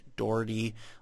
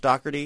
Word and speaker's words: Doherty: It looks like Doherty, but it's supposed to be pronounced Doherty.